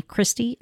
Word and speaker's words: Christy: Email them to me, Christy, C-H-R-I-S-T-Y.